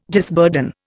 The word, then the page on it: disburden: Indian